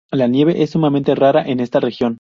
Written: sumamente

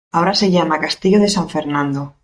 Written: Castillo

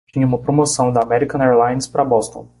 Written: Boston

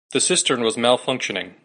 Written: malfunctioning

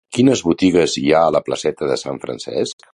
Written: Francesc

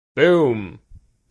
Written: Boom

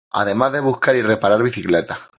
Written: buscar

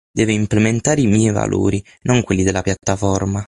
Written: implementare

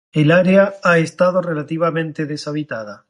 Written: deshabitada

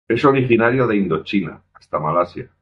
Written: originario

